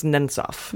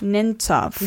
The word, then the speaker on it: Nemtsov: It's pronounced Nemtsov. – Nemtsov.